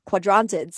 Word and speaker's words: Quadrantids: So this now, this makes it really rare, because Geminids, as well as the Chondratids, so quadrantids, sorry, I'm trying to pronounce it. It's spelled Quadrantid, happens later in the year.